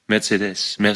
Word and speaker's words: Mercedes: Nein, du sagst Mercedes. Und musst auf die letzte Silbe. Mercedes.